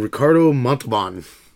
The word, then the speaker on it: Ricardo Montalban: So, Star Trek II: Wrath of Khan came out in 1982, directed by Nicholas Meyer, starring William Shatner, Leonard Nimoy, and Ricardo Montalban.